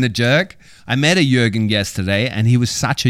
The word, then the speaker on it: Jürgen: So the Ö, the Jürgen.